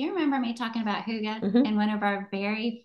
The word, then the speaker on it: hygge: The word is spelled H-Y-G-G-E, but it's pronounced Huga.